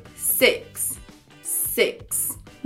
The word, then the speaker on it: six: Número 6, esse som do i tem som de e, e, six, six.